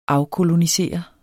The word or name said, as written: afkolonisere